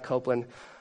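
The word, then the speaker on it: Coupland: It is pronounced Copeland, just so you know.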